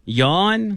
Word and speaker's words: Jan: Yawn? – Yeah. – Why is it yawn? – Well, the J's are pronounced Y in that part of the world.